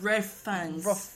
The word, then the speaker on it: Ralph Fiennes: Ralph Fiennes? Is that how you say his name? Or is he like the Reef? – Riff Fangs.